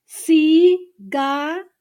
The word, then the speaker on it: cigarillo: Let me break it into syllables. Cigarillo. Okay, listen again. Hang on. Here you go. While you read it. Listen, cigarillo.